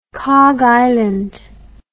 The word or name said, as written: Kharg Island